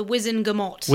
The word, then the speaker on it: Wizengamot: I think the crucial point there is that, was it Wizgamot? Is that how you pronounce it? – The Wizengamot.